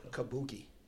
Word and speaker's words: Kabuki: How you pronounce it? – Kabuki.